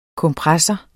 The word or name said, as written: kompressor